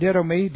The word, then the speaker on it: didomi: And then didomi, D-I-D, long O-M-I.